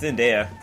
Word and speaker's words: Zendaya: I always fuck up her name. Zendaya? Is that how you say it? – I thought it was Zenata. – Zendaya? – It's Zendaya.